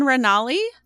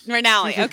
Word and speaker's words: Ranalli: We are here with Lauren Ranalli. Did I say that right? – Yep, Lauren Ranalli. – Okay, Ranalli.